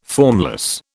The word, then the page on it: formless: British English